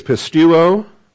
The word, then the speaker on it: pisteuo: The Greek is pisteuo. That's the verb, P-I-S-T-E-U-O, long O with the omega. And then pistis. P-I-S-T-I-S.